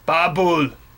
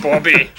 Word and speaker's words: Babel: Babel. – Babel. – We not Bobby.